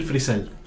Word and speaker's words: Frisell: Se dice, se pronuncia Bill Freisen, ¿no? – Bill Frisell.